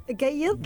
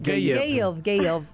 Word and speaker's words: Gayeth: Gayeth. – Gayeth. – Gayeth. – Right? – Okay. – Yes. – So what does this Gayeth mean? – Gayeth came from the Arabic word qayd.